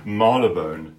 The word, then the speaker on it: Marylebone: Marylebone.